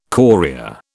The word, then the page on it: chorea: British English